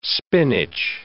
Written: spinach

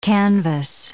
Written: canvass

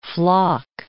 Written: floc

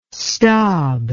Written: stob

